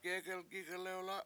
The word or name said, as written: gEgelgIg1lewla7